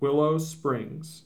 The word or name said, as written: Willow Springs